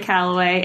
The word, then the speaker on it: Calloway: Oh, this is Joy Calloway, and on Twitter and Instagram, I'm at JoyWCal, C-A-L.